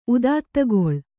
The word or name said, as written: ಉದಾತ್ತಗೊಳ್